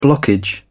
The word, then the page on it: blockage: British English